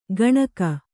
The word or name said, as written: ಗಣಕ